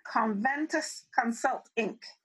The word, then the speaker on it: Conventus Consult Inc: Charlene is from Trinidad and Tobago, the Twin Islands, and she currently is the founder and chief engagement officer, Conventus Consult Inc.